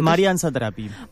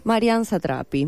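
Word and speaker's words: Marjane Satrapi: Marianne Satrapi. – Marianne Satrapi, muy bien.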